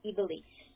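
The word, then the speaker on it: Ivelice: No, I, V as in Victor, E, L, I, C as in Carlos, E, Evely.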